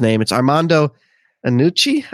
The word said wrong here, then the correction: Iannucci: I believe. Iannucci. Iannucci. Um, he's known for having his actors improvise their lines, which is uh, considering how intense this script is.